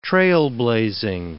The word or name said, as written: trailblazing